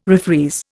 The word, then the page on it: refreeze: Indian